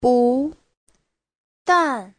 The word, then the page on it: 不但: bu2dan4